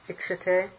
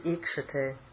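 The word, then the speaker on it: Ikshate (ईक्षते): There's a short E there, Ikshate, on your... – Mm-hmm. – It should be long. It should be Sarvam Ikshate.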